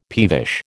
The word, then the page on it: peevish: American English